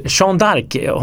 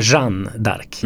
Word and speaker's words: Jeanne d'Arc: Det ska vara Jeanne d'Arc. – Mm. – Och jag säger Jean d'Arc.